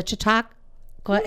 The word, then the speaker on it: Chautauqua: It's a Chautauqua Express. – Chautauqua Express.